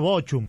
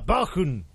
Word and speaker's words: Bochum: Y me dice, bien pronunciado, Sergio, con CH, entre dos vocales se pronuncia como J. ¿Vio que, ¿vio que hablo alemán? – Bueno. Claro, yo lo hubiera dicho Bochum, creo, claro.